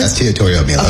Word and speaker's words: Estiatorio Milos: He is CEO of Estiatorio Milos. Did I say that correctly? – Estiatorio Milos.